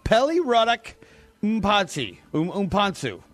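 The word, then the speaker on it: Pelly Ruddock Mpanzu: Pelly Ruddock Umpansu.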